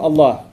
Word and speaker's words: Allah: So we call him Allah. His name is Allah. That would be spelled in English A-L-L-A-H, an emphasis on the L, okay, Allah.